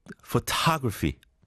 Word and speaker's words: photography: 그러니까 the accent is on the O. 두 번째 음절. Photography, choreography.